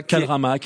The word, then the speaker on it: K-drama: qui drama, je le prononce comme ça. – K-drama, K-drama.